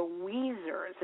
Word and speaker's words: Weezers: Genece became a principal and joined the Weezers. It rhymes with tweezers.